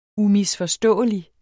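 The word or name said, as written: UMISFORSTÅELIG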